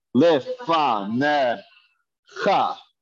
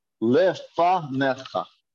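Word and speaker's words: Le fa ne cha (לפניך): Le fa ne cha. The yod has no vowel and the yod is silent. We ignore it. Le fa ne cha.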